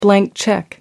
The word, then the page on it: blank check: British English